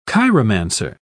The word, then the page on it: chiromancer: British English